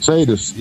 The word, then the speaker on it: Sadus: How do you pronounce that band? Sadus. – Sadus? – Sadus. – Yeah, yes, yeah.